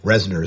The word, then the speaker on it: Reznor: And I think I pronounced his name earlier wrong, but Reznor is his name.